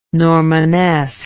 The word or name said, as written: Normanesque